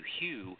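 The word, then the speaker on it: HU: And it's pronounced like the name Hugh, H-U-G-H, Hugh, or H-U-E, Hugh.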